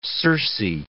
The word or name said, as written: Circe